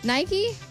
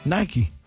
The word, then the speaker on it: Nike: He said most people, the CEO of Nike says that most people pronounce it wrong because it's two syllables and it's technically pronounced like if you were to spell it out, it would be N-A-I-K-E-E.